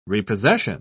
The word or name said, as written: repossession